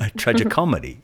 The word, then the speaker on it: tragicomedy: And do you say it like that, Tragic Comedy, or is it tragic? – Yeah. – Because it's all one word. So cool. – Yeah, one word. – A tragicomedy.